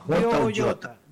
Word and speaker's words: Jota: Jota.